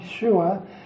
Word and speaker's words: Yeshua: It's somewhat of a shame that in English we mispronounce our Lord's name all the time, since as written in English, it really should be Y-E-S-U-S or Jesus. Pronouncing its original Hebrew form, Yeshua, would be even better,